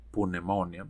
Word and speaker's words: pneumonia: So, pneumonia, que en inglés se escribe con P, pneumonia, pero se pronuncia pneumonia.